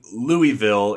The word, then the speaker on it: Louisville: I think it was right after the first one, but in Louisville, and I know I'm saying Louisville, it's Louisville. That's how they say it down in Kentucky.